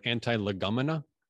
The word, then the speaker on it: antilegomena: There were some books in the New Testament that were spoken against, so to speak, which is the word antilegomena. It's spelled like it sounds. A-N-T-I-L-E-G-O-M-E-N-A. Anti, and then Lego, like the thing you step on, and then mena.